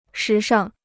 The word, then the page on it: 时尚: shí shàng